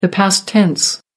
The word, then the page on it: past tense: American English